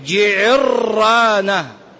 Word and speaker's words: جعرانة: هذه الناس كيف ينطقونها؟ جعرانة جعرانة جعرانة جعرانة، تمشي جعرانة دول المزورين وإخواننا من أصحاب السيارات الأجرة. نطقها الصحيح جعرانة جعرانة، وهي الموضع الذي يحرم به من فاته الإحرام من مسجد التنعيم، مسجد عائشة أم المؤمنين رضي الله عنها وأرضاها.